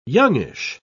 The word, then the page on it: youngish: English; British English